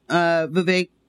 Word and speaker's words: Vivek: So anyway, Vivek rhymes with cake, and he will not bring that cake to Juneteenth because useless holiday.